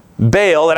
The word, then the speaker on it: Baal: Baal, it actually has a break in it, it would be pronounced Baal, was the god of power, the god of the thunderstorm, the god of the rain.